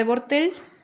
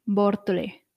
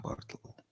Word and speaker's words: Bortle: Para seguir avanzando en la escala de Bortel, podéis leer el poema entero que se titula así, y que ya lo pondré en... – ¿Cómo se escribe? ¿De porque yo... B-O-R-T-L-E, Bortle. – Ah, Bortle.